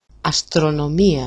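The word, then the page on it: αστρονομία: astronomi-a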